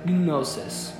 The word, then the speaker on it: gnosis: At the beginning of verse 7, where it says, in an understanding way, understanding there in the Greek is the word gnosis. You have to keep the G silent, so I was trying to pronounce it right. If you'd like to know how to spell this Greek word, it's G-N-O. S-I-S. G-N-O-S-I-S.